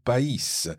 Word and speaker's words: pays: and therefore we pronounce the S on pays, and if you're reading the text, you'll also notice that it's got a circumflex on the I.